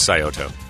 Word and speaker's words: Scioto: They told me when you took me there for breakfast that I was saying it wrong. Scioto's nice. – Scioto Country Club. – Huh? Scioto. What is Scioto? I don't have that. I had back surgery. No. It's the club is Scioto.